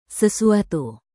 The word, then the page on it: sesuatu: Indonesian